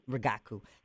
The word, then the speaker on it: Rigaku: He is currently the Global Director of Counter-Narcotics Partnerships at Rigaku Analytical Devices. Did I say that right? Close enough? – Rigaku. – Yeah, accent on the wrong syllable.